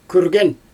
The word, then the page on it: kürgen: Xyzyl